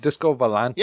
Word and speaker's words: Disco Volante: Disco Volante. – There you go. – Given pronunciation problem, I was going to give it to him. Disco Volante is correct. You got it.